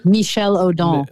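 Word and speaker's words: Michel Odent: And I think it was from, I'm probably going to say his name wrong. Is it O'Day? Is it Michel Odon.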